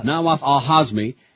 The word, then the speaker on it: Nawaf al-Hazmi: A few days after the Kuala Lumpur meeting, Newsweek has learned the CIA tracked one of the terrorists, Nawaf al-Zahami. Did I say that right? No. Nawaf al-Hazmi, as he flew from the meeting to L.A.